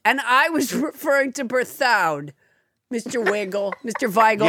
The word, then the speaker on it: Berthoud: But S.J. Weigel wrote in to say it's pronounced Berthoud.